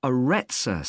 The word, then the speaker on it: Arretsa: It's called the Arezza Centre. That's spelled A-R-R-E-T-S-A.